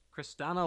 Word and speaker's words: Kristanna: Next, I have Christina. Is it Loken? Loken? – Kristanna Loken. – Is that Kristanna? Is that how it's pronounced? – Kristanna Loken.